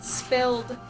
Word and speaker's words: SPLD: SPLD stood for Silent Protest by Lying Down. Toki pronounced it spilled.